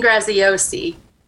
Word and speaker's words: Graziosi: I noticed in your bio that you mentioned Brendan Bouchard and Lee Grazioso. How do you say his last name? – Dean Graziosi.